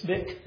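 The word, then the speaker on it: Spicq: There's a commentator named Spick. I think that's how he pronounces his name. It's S-P-I-C-Q. Is it Spick?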